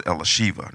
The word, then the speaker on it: Elisheva: My daughter Elizabeth, her name is pronounced Elisheva, not Elizabeth.